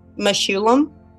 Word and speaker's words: Mechoulam: How do you pronounce the last name? I'm sorry, I stepped on it. – Mechoulam. – Mechoulam.